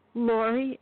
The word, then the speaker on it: Lori: Lori, L-O-R-I. – Okay, Maury, M-O-R-I. – L-O-R-I.